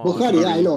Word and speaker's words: Bukhari: I might be pronouncing the Bukhari wrong. – Bukhari, yeah, I know.